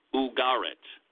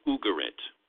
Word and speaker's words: Ugarit: This was from the ancient Canaanite city of Ugarit. Unless you're from the south, then it's probably Ugarit, because they tend to put the accent on the first syllable. I-N-S-U-R-A-N-C.